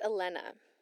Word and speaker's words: Elena: I wanted to add, you guys, my name is pronounced Elena.